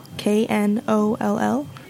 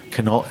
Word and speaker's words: Knoll: Uh, Knoll, K N O L L. – Knoll, it's pronounced Knoll? – Yes.